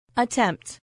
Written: attempt

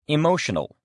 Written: emotional

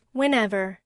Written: whenever

